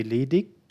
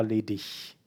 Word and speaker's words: ledig: You can either say ledig or ledig. In southern Germany, usually most of the people would pronounce it ledig, like with a K at the end, ledig, like hard.